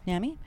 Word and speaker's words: NAMI: Yeah, NAMI.